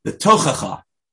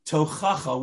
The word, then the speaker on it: Tochacha: Well, it's known as the Tochacha, or in when I was in yeshiva, they call it the Tochacha. It's totally, the emphasis is on the wrong syllables. But the correct word is Tochacha, which means reproof, rebuke.